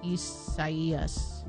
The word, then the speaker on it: Isaías: No, but it's probably the Spanish rendering of it, which in case, if that's the case, then the I is pronounced like an E. – Isaías.